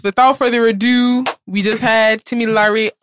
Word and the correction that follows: Tim Lyrie: on. Did I say it right? – Almost. – Say it again. – Tim Lyrie. Yes. – Tim Lyrie.